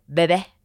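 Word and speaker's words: Bébé: How you have to say it like that? – Yeah, because how the French bring up babies. – Bebe. – Bebe.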